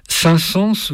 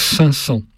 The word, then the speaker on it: Saint-Saëns: Alors, petit détail phonétique, Saint-Saëns voulait que l'on prononçât son nom à l'ancienne, c'est-à-dire Saint-Saëns. Mais, évidemment, le temps a passé et on prononce maintenant plus facilement Saint-Saëns.